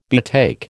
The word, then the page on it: betake: American English